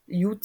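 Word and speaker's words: Jud (יוד): Jud se vyslovuje buď to jako i nebo jako j.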